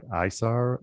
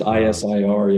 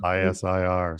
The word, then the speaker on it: ISIR: ISAR or ISAR? – Just ISIR, yeah. – ISIR.